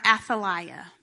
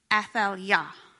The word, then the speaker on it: Athaliah: So I'm going to call her Athaliah, but that is not how you actually say her name. Her name is actually Athaliah.